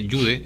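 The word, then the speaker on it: Yehude: Ahora, doctor Gamarra, pasando al tema de este proyecto de ley presentado por vicepresidente del Congreso, Jude Simón, aunque él dice que se pronuncia Jude, es probable que sea así, Jude Simón, para hacer que las sesiones del Congreso, algunas sesiones que decida la mesa directiva, sean en condición de reservada.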